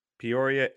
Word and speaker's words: Peoria: He's down in Peoria. I don't know if I said that right. – Peoria, Illinois. – There we go.